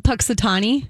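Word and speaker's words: Punxsutawney: Puxifani Phil. – Oh, isn't it Puxitani? – Puxitani. Okay. – Yeah, Phil, I think it's Puxitani.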